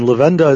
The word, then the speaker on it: Levenda: And Levenda is spelled L-E-V as in Victor, E-N-D-A.